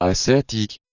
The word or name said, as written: ascetique